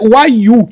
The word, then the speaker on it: Wayuu: E aí você tem, né, uma família que é de nativos, a pronúncia é Wayuu, que são são ameríndios, né, que vivem ali entre a Colômbia e a Venezuela.